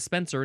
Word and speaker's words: Spencer: I am not a censoring person, even though my name is Spencer and it rhymes with censor.